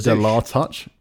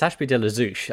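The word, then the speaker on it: Ashby de la Zouch: De la Touch. What? Is that how you say it? How do you say it? – Well, it's Ashby de la Zouche.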